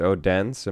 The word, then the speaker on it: Odense: So we start in the first round against Odense. Am I saying that correctly? – You are.